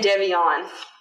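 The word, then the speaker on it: Devyion: Like, I always say Devion.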